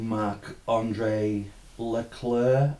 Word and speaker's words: Marc-André Leclerc: It's about a kid called Marc-André Leclerc, I think it's pronounced.